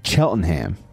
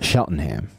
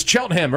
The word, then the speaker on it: Cheltenham: In this conversation with Adam Kaplan, we start from the beginning, where he grew up in Cheltenham, not Sheltenham. – I have friends growing up who couldn't pronounce it correctly. It's Cheltenham.